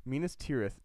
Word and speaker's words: Minas Tirith: Minas Arnor was renamed Minas Tirithil. Tower. Is that, I said that wrong? – Minas Tirith.